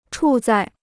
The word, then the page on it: 处在: chǔ zài